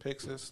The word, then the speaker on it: Pixis: Pixish. – Pixis. – Yeah. – Yep. – Yeah, that's how they say it in Japanese.